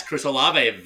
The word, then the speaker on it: Olave: Olave, the new rookie for the Saints? – Yes, Chris Olave.